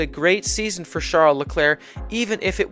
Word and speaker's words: Leclerc: Finishing third in the Drivers' Championship was Ferrari driver Charles Leclerc, spelled Charles Leclerc, but apparently pronounced Charles Leclerc.